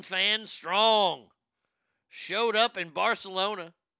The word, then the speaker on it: Barcelona: In Barcelona, the C is pronounced TH there.